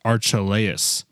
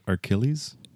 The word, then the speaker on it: Archelaus: Is that Archilles? – A-R-C-H. – Because Santa Claus is spelled C-L-A-U-S. So this is actually Archelaus. Okay, we're going to go with Herod Archelaus.